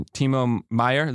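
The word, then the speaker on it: Timo Meier: Timo Meyer, is that how you say it? – Yep.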